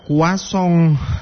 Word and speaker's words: croissant: Sama kayak croissant. Itu kan kalau di tulisannya dibacanya itu kayak croissant. Tapi pas diucapin ternyata beda, kuasong. Aduh, kalau di Indonesia mah bukan kuasong, kuah asam, kuah bugis, kuah sup itu.